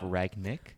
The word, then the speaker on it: Rangnick: What is it? Ralf Rangnick? Is that it? – Rangnick. – Okay, just call him Ragnar. – It's not. It said exactly how it's spelled.